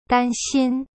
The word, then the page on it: 担心: dan xin